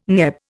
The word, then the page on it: NEB: Indian